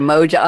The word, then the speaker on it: moja: But you know what stood out for me was your word, the new word I just learned, Moja. How do you spell that? I know it's a Swahili word, but how do you spell that, in English? – So the word is M-O-J-A, Moja.